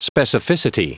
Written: specificity

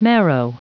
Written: marrow

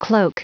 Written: cloak

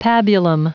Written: pabulum

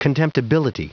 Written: contemptibility

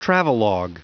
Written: travelog

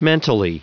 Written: mentally

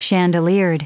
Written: chandeliered

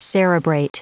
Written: cerebrate

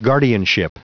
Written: guardianship

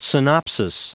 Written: synopsis

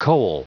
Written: coal

